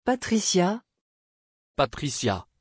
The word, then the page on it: patrícia: French